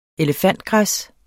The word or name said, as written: elefantgræs